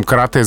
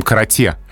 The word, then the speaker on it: карате: Если мы пишем карате с буквы «е», это не значит, что мы произносим карате.